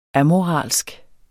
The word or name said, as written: amoralsk